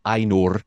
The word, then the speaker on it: Ainur: They're called the Ainur. Just roll your R's, Mary.